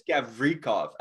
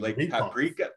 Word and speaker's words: Gavrikov: I think it's Gavrikov, actually. Gavrikov. Like paprika.